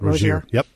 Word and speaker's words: Rozier: Terry Rozier, am I saying that right? – Rozier.